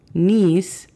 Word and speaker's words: niece: Niece significa sobrina. La pronunciación fonética de esta es N-I-S.